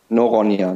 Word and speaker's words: Noronha: Noronha mit N, genau. – Noronha, Noronha, sehr cool. Und wird wahrscheinlich auf Portugiesisch ganz anders ausgesprochen, wie ich es gerade ausgesprochen Nö, das klang schon nicht schlecht.